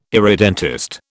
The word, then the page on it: irredentist: British English